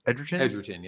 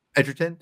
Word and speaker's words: Edgerton: Is it Edgerton? – Edgerton, yeah. – Edgerton.